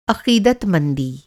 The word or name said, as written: अक़ीदतमंदी